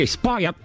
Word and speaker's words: spoiled: Aqui, spoiled. Você engole o finalzinho. – Spoiled. – Isso. – Spoiled. – Tá vendo? Não é spoiled. Não é língua no céu da boca.